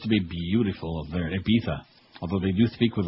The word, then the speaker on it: Ibiza: Ibiza, you got to pronounce it right and spell it right. You got it, Ibiza? – We'll put it I-B-I-Z-A, on Ibiza in Spain.